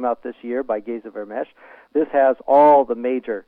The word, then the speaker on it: Vermes: It's 1995, the Dead Sea Scrolls in English, Geza Vermesh, and the last name there is Vermes, pronounced like SH.